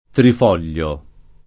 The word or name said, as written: trifoglio